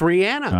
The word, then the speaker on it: Rihanna: It's Rihanna. – I can do that. – That's how she pronounces it herself.